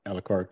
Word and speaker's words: Alucard: How do you pronounce it? Alucard? – Yeah, Alucard. Yeah, it's like Dracula backwards.